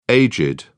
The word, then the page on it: aged: British English